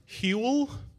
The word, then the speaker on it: Huel: Huel? – Yeah. Yeah. It even rhymes with gruel.